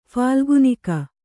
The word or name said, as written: ಫಾಲ್ಗುನಿಕ